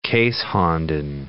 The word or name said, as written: keeshonden